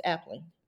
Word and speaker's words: Appling: Or is it Appling? Did I say it right? – You're right. It's Appling.